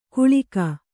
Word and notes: ಗುಳಿಕ: Kannada

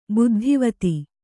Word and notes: ಬುದ್ಧಿವತಿ: Kannada

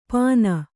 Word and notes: ಪಾನ: Kannada

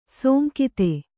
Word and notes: ಸೋಂಕಿತೆ: sōnkite

